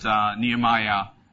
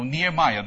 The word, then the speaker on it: Nehemiah: Nehemiah has this H in it, and that H is silent in our language. Ah, there it is. Yet it's not silent in the Hebrew language. In Hebrew, in fact, it's that K sound.